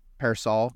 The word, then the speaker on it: Pearsall: Wide receiver Ricky Pearsall. I don't know if I'm pronouncing that right. Maybe Pearsall. – I think that's right. Pearsall.